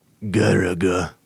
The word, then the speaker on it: Galaga: Galaga, or as it's known in Japan, Garaga.